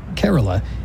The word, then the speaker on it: Kerala: This one comes to us from Kerala, India. Am I saying that right? Kerala? Renee writes in... Kerala.